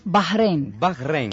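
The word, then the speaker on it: Bahréin: ¿Cómo se pronuncia bien? – Bahrein. Claro, porque la H...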